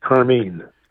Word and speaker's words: Carmine: You know, I guess I've been saying, how do you pronounce Carmine? Is it Carmen? Is that how you pronounce it? I've been pronouncing it Carmine. – Actually pronounce it Carmine. – Carmine. Okay, Carmine. – Yeah. Yeah, like the red color.